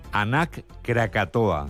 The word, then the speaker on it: Anak Krakatoa: Lo decimos bien. Anak Krakatoa. – ¿Lo he dicho bien? – Sí. – Sí, parece euskera, pero es indonesio.